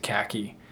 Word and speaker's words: CACI: And for those listening not familiar with our company, CACI, we also do pronounce it Khaki.